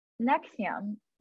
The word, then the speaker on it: NXIVM: But first, I'd love to just backtrack a bit for anyone who's like never heard of the NXIVM. Am I even saying it right? NXIVM. – Yeah, you are.